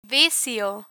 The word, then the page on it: vicio: Spanish